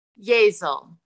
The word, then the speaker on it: Yazel: Yazel. – Okay, Yazel. All right. I don't know if it was Yazelle or I was going with Yazelle, just rhyming with Giselle.